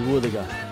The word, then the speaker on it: wuthuga: Woothaga is spelt W-U-T-H-U-G-A. Woothaga. Can you say woothaga?